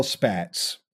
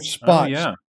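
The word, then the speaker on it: Spaatz: The other person I think would probably be Carl Spatz or Spatz, depending on how you want to pronounce it.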